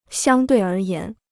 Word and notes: 相对而言: xiāng duì ér yán

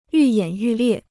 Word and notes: 愈演愈烈: yù yǎn yù liè

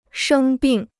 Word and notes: 生病: shēng bìng

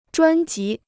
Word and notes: 专辑: zhuān jí

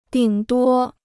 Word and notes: 顶多: dǐng duō

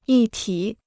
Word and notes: 一体: yī tǐ

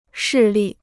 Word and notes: 势力: shì li

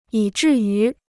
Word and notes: 以至于: yǐ zhì yú

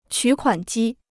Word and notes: 取款机: qǔ kuǎn jī

